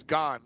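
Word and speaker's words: Ghim: So how do you pronounce? What is he said? Doug Gahan? – Gahan, G-H-A-N, is it? – Yeah, G-H-I-N, but yeah. I guess it's Gahan. He would know.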